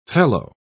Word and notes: felloe: British English

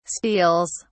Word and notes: steals: British English

